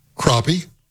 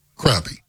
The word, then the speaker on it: crappie: And I know there are regional differences, and anglers have different pronunciations. Crappie, crappie. Which is it for you? – I've heard crappie.